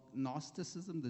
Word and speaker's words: Gnosticism: And then there was also some believers in, it's called Gnosticism, the G is silent.